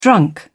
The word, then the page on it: drunk: British English